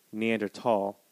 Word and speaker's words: Neanderthal: I mispronounced it. Neanderthal.